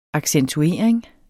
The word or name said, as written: accentuering